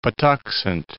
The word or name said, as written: Patuxent